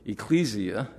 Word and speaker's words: ecclesia: ecclesia, I believe is how it's pronounced in the Greek, it simply means the called out ones.